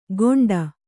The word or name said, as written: ಗೊಂಡ